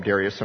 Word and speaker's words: Darius: That very night, Belshazzar, king of the Chaldeans, was slain, and Darius, and I usually call him Darius, because when I used to call him Darius, somebody said, no, the proper pronunciation, non-Western, is Darius.